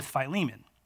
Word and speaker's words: Philemon: I didn't even know how to pronounce that word at first, and there's actually some debate about how you pronounce it, so we're going to go with Philemon.